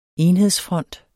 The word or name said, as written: enhedsfront